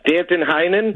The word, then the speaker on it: Danton Heinen: This kid, please correct me if I'm pronouncing his name wrong, Danton Heinen? – Yes.